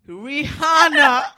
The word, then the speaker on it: Rihanna: The H is silent. The Rihanna. – H is silent. – Rihanna. – Okay. – Mm-hmm. – Rihanna. – Rihanna. Not huh.